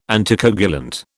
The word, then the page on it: anticoagulant: British English